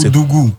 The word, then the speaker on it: Koudougou: Ouais, Koudoubou. Attends, pardon, il faut bien prononcer. – Koudougou. – Koudougou. – C'est genre Koudougou, tu vois.